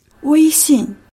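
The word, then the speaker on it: Weishing (微信): Und jetzt muss ich noch schnell erklären, warum Samuel da von Weishing und nicht WeChat redet. Habe ich das einigermaßen richtig gesagt, Peter? Weishing. komm, wir spielen mal ein, wie es richtig tönt. – Weishing.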